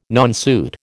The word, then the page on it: nonsuit: American English